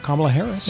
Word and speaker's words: Kamala Harris: Big week for Kamala Harris.